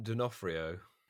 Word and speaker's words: D'Onofrio: So we have Vincent D'Onofrio. No. – Do you want me to help? – Please, if you know. – I do. It's Vincent D'Onofrio. – D'Onofrio.